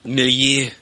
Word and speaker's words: milieu: Milieu. – Milieu. – All right. – Like a frosh. – Okay. M-I-L-I-E-U. Now, this is fucking with me, man. – Why aren't we paused? – Milieu. It's pronounced milieu.